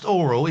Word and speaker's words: aural: An aural, but it's pronounced aural, even though it's spelled A-U-R-A-L.